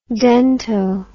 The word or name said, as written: dento